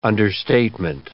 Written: understatement

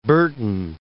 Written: Berton

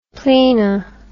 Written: plena

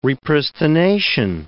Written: repristination

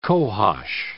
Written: cohosh

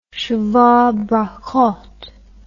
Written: sheva brachoth

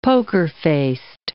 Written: poker-faced

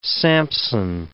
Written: Samson

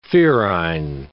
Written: ferine

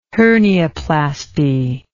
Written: hernioplasty

